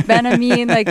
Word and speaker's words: Benjamin: Wow, even did the Benjamin, didn't pronounce the J, like, wow.